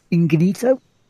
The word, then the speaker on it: Ingenito: Uh, a person called Kaylee Plagge spoke up last week concerning allegations of sexual abuse against former editor Vince Ingenito. Ingenito, which that's, that's, that's a much better way of pronouncing the name.